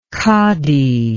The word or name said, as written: kadi